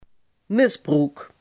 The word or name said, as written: misbruik